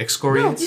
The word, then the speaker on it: excoriates: Did I say that right? There's no way I said that. Excoriates? – No, you said it right.